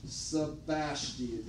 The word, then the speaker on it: Sebaste: It's spelled like this. I'll write it up here. Via Sebaste. This road that goes right up through here, up in this area. Sebaste. That's how you would say it. You can't even read my writing. S-E-B-A-S-T-E.